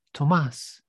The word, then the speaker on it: Tomas: my name comes from Spanish, so it's pronounced Tomas. And it's spelled Tomas, no H.